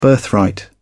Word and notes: birthright: British English

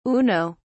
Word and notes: uno: British English

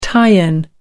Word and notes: tie-in: American English

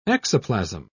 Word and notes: exoplasm: British English

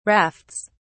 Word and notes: rafts: British English

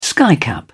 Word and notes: skycap: British English

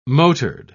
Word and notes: motored: British English

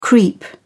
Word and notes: creep up: British English

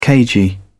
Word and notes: cagey: British English

